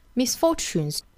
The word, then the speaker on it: misfortunes: 首先 misfortunes, 那我们来看这个单词呢，里面有一个 t 和 u 的字母组合。其实这里面应该把它读成一个吃的发音。我们再听一下原声呢，也是这个单词是读成一个 misfortunes, 而不是 misfortunes, 是吃哈 misfortunes.